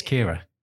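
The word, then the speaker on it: Keira: Because you said Kia. I think it's Kiara, isn't it? – Oh, well, that's how it's spelled, but I think it's Kira.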